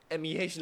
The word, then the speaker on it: Chap Go Mei: Chap Go Mei. It's a famous Chap Go Mei. Some people pronounce it as Chap Go Mei, MEH lah.